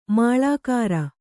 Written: ಮುಳ್ಳುಕೀರೆ

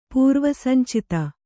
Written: ಪೂರ್ವಸಂಚಿತ

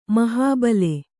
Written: ಮಹಾಬಿಲ

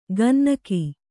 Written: ಗುನ್ನಿಗ

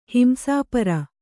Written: ಹಿಂಸಾಪರ

